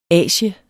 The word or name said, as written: asie